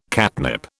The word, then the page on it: catnip: British English